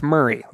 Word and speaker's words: Maury: Remember, Murray spelled M-A-U-R-Y. Pronounce Murray, like Bill.